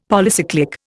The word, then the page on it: Polycyclic: Indian